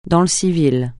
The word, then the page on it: dans le civil: francais